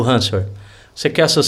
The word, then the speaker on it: ransomware: Ransomware.